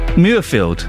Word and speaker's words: Muirfield: which begins this morning at Murrayfield. Is that a place? Is that how you say it? Muirfield. It's spelt Muirfield, but I've never heard of Muirfield. I've heard of Murrayfield.